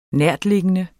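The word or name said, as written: NÆRTLIGGENDE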